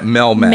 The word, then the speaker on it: Melmac: He comes from the planet Melmac. – Melmac.